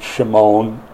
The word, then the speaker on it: Shimon: I think Jews today would pronounce it Shimon. S and SH are the same letter, so it just depends on how you pronounce it historically.